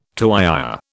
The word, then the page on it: tuyère: British English